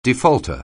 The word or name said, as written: defaulter